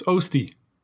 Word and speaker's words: Oeste: Did I pronounce that correctly, Gord? – Yeah, pretty close. Oeste.